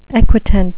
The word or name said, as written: equitant